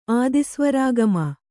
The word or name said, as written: ಆದಿಸ್ವರಾಗಮ